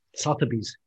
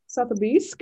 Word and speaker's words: Sotheby's: Und jetzt geht das Auktionshaus Sotheby's, hilf mir bei der Aussprache. – Sotheby's. – Sotheby's, genau. Äh, die gehen jetzt an den Start und äh, ja, auktionieren quasi wieder etwas.